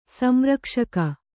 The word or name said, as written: ಸಂರಕ್ಷಕಿ